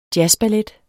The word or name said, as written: jazzballet